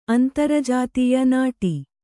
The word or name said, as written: ನಾಡಿ